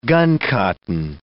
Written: guncotton

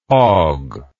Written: hawg

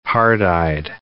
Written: hard-eyed

